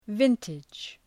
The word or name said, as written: vintage